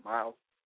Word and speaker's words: Myles: All my name is spelled Myles with a Y, so it's Myles, M-Y-L-E-S, W-M-I-L-L-E-R.